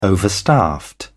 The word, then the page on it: overstaffed: British English